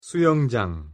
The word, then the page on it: 수영장: Korean